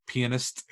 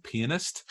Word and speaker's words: pianist: Here we have him playing a pianist, who, and I'll say that again for anyone who thought I said that wrong, pianist.